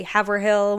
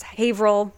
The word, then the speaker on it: Haverhill: I mean, people from out of town say Billerica when it's really Billerica. They say Haverhill when it's Haverhill.